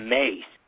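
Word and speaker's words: Myss: You know, you worked with, and your friend Caroline Mice. Am I pronouncing that last name right? Correctly? – She pronounced it Mace. Although her mother and brother have two other pronunciations.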